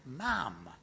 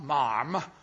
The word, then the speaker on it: Ma'am: And then you follow up and you must say, Ma'am, rhyming with ham, not marm, rhyming with harm.